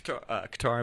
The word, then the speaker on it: Qatar: Qatar, I believe, is how it's pronounced.